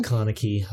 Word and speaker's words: Kaneki: And then number two was Kaneki, I think is how you say it, from Tokyo Ghoul. – Yeah, Kaneki.